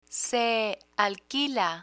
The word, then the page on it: se alquila: spanish